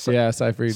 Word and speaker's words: Seyfried: Seyfried, is that how you say it? – Seyfried. – Seyfried.